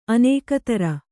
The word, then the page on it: ಅನೇಕತರ: kannada